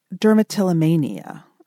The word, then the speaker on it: dermatillomania: You also have a form of OCD called dermatillomania. Did I pronounce it right? – Yes.